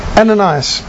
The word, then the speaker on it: Ananias: And this first convert was Ananias, that's a better pronunciation.